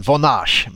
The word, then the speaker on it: Vonage: Bolaget har haft eh, må många namn under dagen, men jag tror att Vonage är eh, mm. – uttalet. Även hört franska varianten Vonage, men jag tror Vonage är, är vad det ska heta.